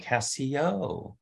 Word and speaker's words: Casio: Catherine says, for $1.99, says pronounced Casio. Casio. All right, Casio.